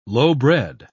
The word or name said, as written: lowbred